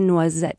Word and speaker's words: noisette: And in French, that would be a café noisette. – Café noisette. – Café noisette. – A good word. And it's hard for me to pronounce noisette.